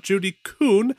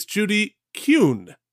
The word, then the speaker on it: Kuhn: We got a live objection from Jillian, who reminded me it's not Judy Kuhn, it's Judy Kuhn.